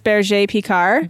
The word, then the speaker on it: Berger Picard: Burger, how do you say it? – Well, the way you're supposed to say it is berger picard. – Berger picard. – But we learned quickly not to say it that way